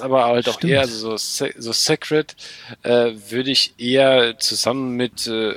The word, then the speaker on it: Sacred: Du meinst, ich meine aber, glaube, das wird Sacred ausgesprochen. – Ja, oder so. Ja. Aber das war jetzt. Sacred von Ascaron, ja.